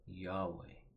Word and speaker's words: Yahweh: In Latin, the letters are YHWH, and we simply pronounce that Yahweh.